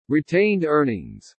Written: RETAINED EARNINGS

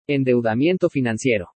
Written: ENDEUDAMIENTO FINANCIERO

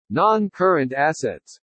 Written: NON-CURRENT ASSETS

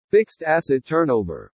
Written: FIXED ASSET TURNOVER

